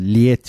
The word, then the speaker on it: Lied: Yani Lüleburgaz, İzmir, Edirne, Deniz, Lied şeklinde yazılıyor. Lied diye okunuyor.